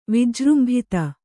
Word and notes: ವಿಜೃಂಭಿತ: vijřmbhita

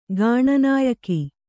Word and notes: ಗಾಣನಾಯಕಿ: Kannada

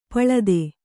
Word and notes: ಪಳಿತ: paḷita

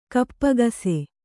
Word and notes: ಕಪ್ಪಗಸೆ: kappagase

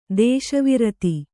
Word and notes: ದೇಶವ್ರತಿ: dēśa vrati

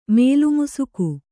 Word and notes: ಮೇಲ್ಮುಸುಕು: mēlmusuku

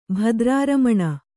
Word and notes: ಭದ್ರಾರಮಣ: kannada

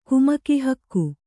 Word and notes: ಕುಮಕಿಹಕ್ಕು: kumaki hakku

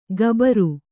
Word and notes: ಕಬರು: kannada; kabaru

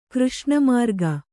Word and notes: ಕೃಷ್ಣಮೃಗ: křṣṇa mřga

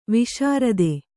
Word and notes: ವಿಸೃತ: visřta

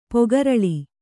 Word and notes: ಪೊಗರಳಿ: Kannada

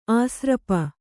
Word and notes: ಆಸ್ರಪ: āsrapa

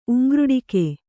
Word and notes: ಉಂಗ್ರುಡಿಕೆ: uŋgruḍike

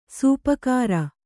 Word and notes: ಸಾಪುಗಾರ: Kannada

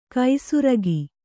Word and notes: ಕೈಸುರಗಿ: kai surugi

